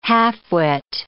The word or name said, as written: half-wit